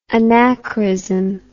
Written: anachorism

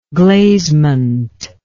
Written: glazement

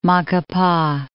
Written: Macapa

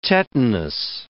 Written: tetanus

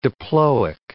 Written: diploic

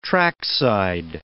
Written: trackside

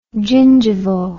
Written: gingivo

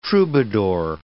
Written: troubadour

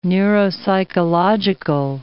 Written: neuropsychological

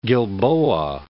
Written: Gilboa, Mount

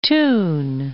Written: Thun

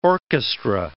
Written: orchestra

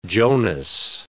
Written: Jonas

